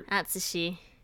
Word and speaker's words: Atsushi: Atsushi. – If you're going with a silent U for Tsu.